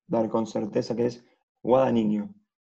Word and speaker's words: Guadagnino: Yo vengo a enseñarles se pronuncia, la tilde se pone como si fuese una palabra grave y se pronuncia guadagno.